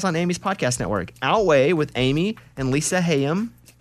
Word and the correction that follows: Haim: Haim. – Yep. Yeah. It's spelled Haim.